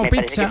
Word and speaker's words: pizza: La Z no es la Z como es decimos en catalán tan sonora, no. Es achurra. – Muy bonito, muy bonito. – Es como pizza. – Se dice así, sí.